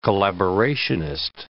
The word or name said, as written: collaborationist